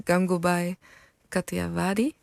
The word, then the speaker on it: Gangubai Kathiawadi: Je hebt een paar films getipt en daarvan heb ik er een paar gezien en die me het meest bijblijft is Gangubai Kathiawadi.